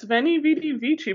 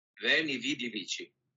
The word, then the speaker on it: Veni, Vidi, Vici: Yeah, it's Veni, Vidi, Vici, but maybe I'm saying it wrong. Okay, wait, let's play this little Google sound. – Veni, Vidi, Vici.